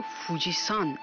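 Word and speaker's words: فوجی‌سان (富士山): ژاپنی‌ها این کوه رو فوجی‌سان تلفظ می‌کنند. این سان پسوند احترام که به نام افراد اضافه میشه نیست، بلکه نحوه خوانش کانجی کوه.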